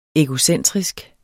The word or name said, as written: egocentrisk